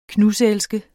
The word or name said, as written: knuselske